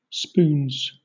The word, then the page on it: spoons: British English